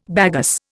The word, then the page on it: bagasse: Indian